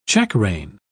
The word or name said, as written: checkrein